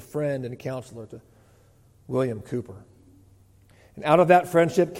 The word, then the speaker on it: Cowper: Looks like Cowper. You pronounce it Cooper, I'm told.